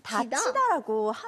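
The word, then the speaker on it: 닫히다: 그런데 이거 발음할 때는요, 똑같이 다치다, 다치다라고 합니다.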